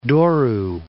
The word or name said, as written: Douro